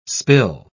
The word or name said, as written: spill